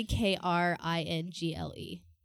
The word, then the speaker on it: Kringle: It's literally K R I N G L E. – K R. Spell it again. – K R I N G L E. – Kringly.